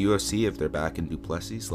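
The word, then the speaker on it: Du Plessis: Yeah, Duplessis.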